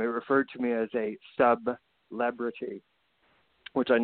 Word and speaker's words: Sub-Lebrity: Sub-Lebrity. Yes, we got to pronounce that hyphen because I am not a celebrity. Sub-Lebrity.